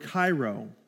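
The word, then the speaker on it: kairo (χαίρω): See, the word rejoice in ancient Greek is the word kairo, pronounced much like, you know, the news station kairo.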